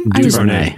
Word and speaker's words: DuVernay: DuVernay.